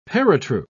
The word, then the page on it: paratroop: British English